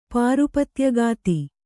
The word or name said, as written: ಪಾರುಪತ್ಯಗಾತಿ